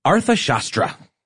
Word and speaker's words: Arthashastra: I mean, generally little known, certainly known to some, called The Arthashastra. – Well done. – Did I get it? – I think you did. The Arthashastra.